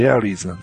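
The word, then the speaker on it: Ares: ねえ、アメリカのコンステーション計画でもう終わっちゃいましたけどね、私ロケットもアレス 1、アレス 5 だったけど、まあ、英語の発音ってエアリーズなんですよね。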